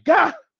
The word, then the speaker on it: gah: They go like, gah! and it's spelled just like that, G-A-H.